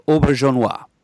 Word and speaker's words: Auberjonois: Again, Murd, Rene Auberjonois.